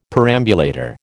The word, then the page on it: perambulator: American English